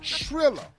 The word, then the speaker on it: Triller: No, I did not. I said Triller. Nobody said a damn thing about Thriller. – It's just a Thriller. – That's not what this is. It's Triller. Rhymes with Gorilla.